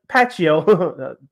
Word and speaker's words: Pacheco: Isaiah Pacquiao, Pacheco, thank goodness I pronounced his name wrong last time for the season.